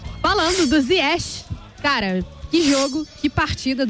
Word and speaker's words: Ziyech: A gente tem a pronúncia Ziyech, marroquina.